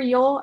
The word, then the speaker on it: Yol: But then like with Aquas, actually, I started with just like a little like for Yol. I didn't like any of the other characters, but my Yol liking was just like a little bit. And then, like... – Oh, my God, yeah, it's pronounced yo.